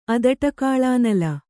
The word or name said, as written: ಅದಟಕಾಳಾನಲ